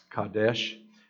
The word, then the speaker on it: Kadesh: Anyone want to pronounce it for me? It's Kadesh.